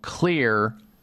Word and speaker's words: Xlear: I would, one, go to the store, the health food store or some store, and get something called Clear, X-L-E-A-R. That's how you spell it, X-L-E-A-R, Clear Rescue Nasal Spray.